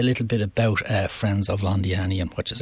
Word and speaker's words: Londiani: Did I pronounce that right, Londiani? – You did, absolutely perfectly right.